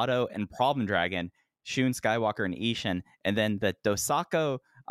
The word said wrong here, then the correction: Dosanko: let me pronounce that again, Dosanko Derby, six-man tag team match, KZ, Big Boss Shimizu, Jackie Funky Kamei versus BB Hulk, Kai, and Hyo.